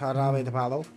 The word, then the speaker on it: Jarabe de Palo: pronounced Jarabe de Palo.